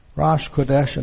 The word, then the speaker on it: Rosh Chodesh: And pronounced Rosh Chodesh, and then whatever month it is.